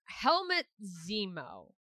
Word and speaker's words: Helmut Zemo: Helmut Zemo. Am I saying that right? – Yeah, sure.